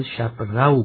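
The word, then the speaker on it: شب‌رو: شب رو که البته شب رو باید گفت، مثل خسرو، مثل راه رو، راه رو غلط است.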